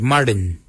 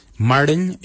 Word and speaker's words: Marrdiny: Kujo is spelled G-O-O-D-J-A-L. Kujo. For 3, we say Marin. Marin is 3. Marin is spelled M-A-R-R-D-I-N-Y. Marin. Marin for 3. For 4, we say Kujo, Kujo. Kujo, G-O-O-D-J-A-L. And then again, G-O-O-D-J-A-L.